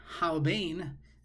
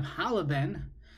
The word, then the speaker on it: Halavain: If you pronounce it wrong, "Halbain" instead of "Halavain," so then "Halbain" sounds like "Halbain chateinu kashelag," that you're asking Kadosh Baruch Hu to take our sins and make them white.